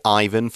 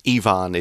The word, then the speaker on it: Ivan: So you can say Ivan for this name, but I think in Russian, Ivan is more ah.